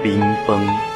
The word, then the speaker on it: 冰饭: 今天要教大家的闽南语是冰饭，读作冰崩。冰饭，读作冰崩。